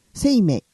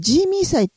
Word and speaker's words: 清明: 清明。 – 清明。 – はい。清いに明るいと書いて。 – これ、ほら、沖縄とかではジーミー祭っていうのあるじゃないですか。 – はいはい。 – あれのジーミーって、清明って書いてジーミーって読みますよね。